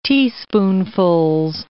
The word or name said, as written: teaspoonfuls